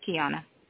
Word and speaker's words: Kiauna: Yes, it's Kiana. – Will you spell that, please? – Mm-hmm. K-I-A-U-N-A.